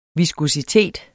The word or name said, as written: viskositet